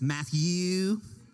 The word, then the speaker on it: Matthew: And when they say my name, it's Matthew. It's really long, really drawn out.